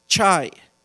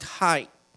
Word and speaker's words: chai: And the Hebrew word for life is the word chai, C-H-A-I, and somebody else, they pronounce it hai, H-E-I, but basically the same thing.